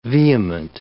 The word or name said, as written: vehement